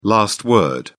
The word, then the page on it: last word: British English